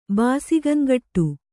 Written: ಬಾಸಿಗಂಗಟ್ಟು

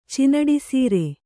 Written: ಚಿನಡಿಸೀರೆ

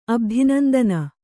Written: ಅಬ್ಧಿನಂದನ

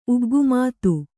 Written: ಉಗ್ಗುಮಾತು

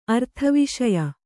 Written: ಅರ್ಥವಿಷಯ

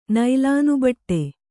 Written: ನೈಲಾನುಬಟ್ಟೆ

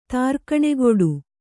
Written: ತ್ರಿಗುಣಕೂಟ

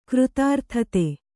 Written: ಕೃತಾರ್ಥತೆ

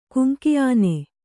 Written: ಕುಂಕಿಆನೆ

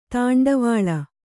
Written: ದಂಡವಳ

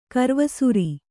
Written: ಕರ್ವಸುರಿ

